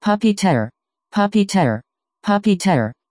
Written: puppeteer

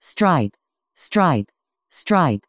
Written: stripe

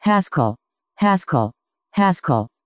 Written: haskell

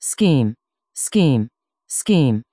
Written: scheme

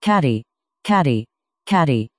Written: caddy

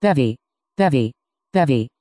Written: bevy

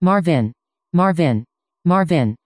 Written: marvin

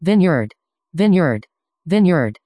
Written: vineyard

